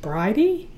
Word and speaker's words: Brighde: No, but we do have Bridie, which is spelled B-R-I-G-H-D-E. – That's pronounced Bridie? – Yep. – Okay.